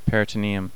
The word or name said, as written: peritoneum